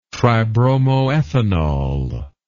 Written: tribromoethanol

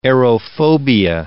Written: aerophobia

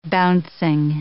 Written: bouncing

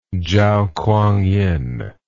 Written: Chao Kuang-yin

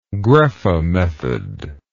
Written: graeffe method

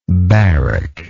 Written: baric

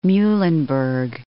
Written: Muhlenberg

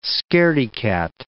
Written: scaredy-cat

